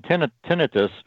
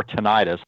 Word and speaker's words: tinnitus: And tinnitus or tinnitus. I come from an operating room background. So when we say, sometimes the accent is on a different syllable when you're in the operating room or in the healthcare environment, but I've heard it called tinnitus by the docs and tinnitus in the advertisements on the television promoting some cure for it.